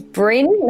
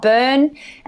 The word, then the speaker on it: Bryn: His name is Bryn, not Burn, and not Brian, but Bryn. – How do you spell it? – Bryn. – Yeah. B-R-Y-N.